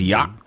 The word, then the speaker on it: yacht: Apparently it was Yacht Rock Night. Yacht Rock Night. – Yacht? Yeah. Can you spell that? – Y-A-C-H-T. – Yacht. Oh, yacht. Like the boat.